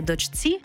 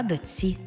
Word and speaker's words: дочці: Пишемо «чці», а вимовляємо «ці». Наприклад, не «дочці», а «доці».